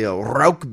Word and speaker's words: Rauch: And then our another flagship is a Rauch beer, which is a smoked lager.